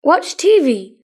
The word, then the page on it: watch tv: British English